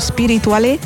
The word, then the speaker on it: Spirituale: Oggi arriva anche il singolo Spirituale con l'accento sulla E e ha scelto di cantarla con Saif.